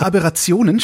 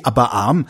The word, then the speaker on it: Aberration: Die chromatischen, die chromatischen Aberrationen schreiben sich nur mit einem B. Ausgesprochen werden sie korrekt mit einer Art kleinem Stopp nach dem B, also Aberration und nicht wie aberarm.